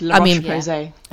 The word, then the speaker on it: La Roche-Posay: but it's La Roche-Posay or something like that. – Yes, La Roche-Posay.